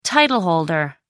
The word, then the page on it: title-holder: American English